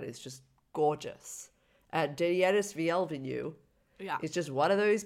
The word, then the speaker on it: Villeneuve: I was going with Denis Villeneuve because he's French. – Villeneuve.